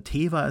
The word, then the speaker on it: Teva: Und wir haben mit Carson Teva sogar noch dann, oder Teva, ich habe beim letzten Mal gesagt, Teva immer, ne, glaube ich. Äh, Teva ist die offizielle oder richtige äh, Aussprache des Namens.